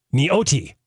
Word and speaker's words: Nyati: Nyoti is how you pronounce it. N-Y-A-T-I pronounced Nyoti.